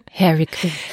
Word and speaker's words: Hairy Queen: Also nicht Harry, der Enkel von der Queen, sondern Hairy Queen. – Harry, wie haarig, ganz genau.